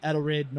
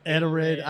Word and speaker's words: Edelrid: Vice President of Edelrid North America. Did I say it right? Edelrid. Oh, shit. – Edelrid.